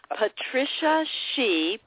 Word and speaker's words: Patricia Shih: It's Patricia Shih, P-A-T-R-I-C-I-A.